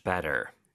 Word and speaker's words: better: Much better. – 네, 빈칸에는 Better, BETTER. – 네, better. T가 두개 들어가지만, 물론 하나만 발음하고, 모음 사이에 껴서 리을처럼 부드럽게 발음이 되죠?